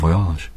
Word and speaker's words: voyage: Bon voyage. – Good journey. – Bon voyage. – Bon voyage. – Voyage. – Voyage. – Yeah. – Soft G. Voyage. – Bon voyage. B-O-N.